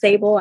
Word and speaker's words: Sable: Hi, my name is Sable. I don't know if you heard this before, but it's pronounced Sable. I know it's a very strange spelling.